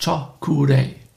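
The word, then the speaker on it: Choku Rei: Die Aussprache ist Chokurei. Chokurei. Ja.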